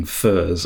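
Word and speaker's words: Phở: But if it is, Faux's menu cheerfully reads, Faux, pronounced fur, is the national dish of Vietnam.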